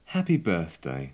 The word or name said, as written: happy birthday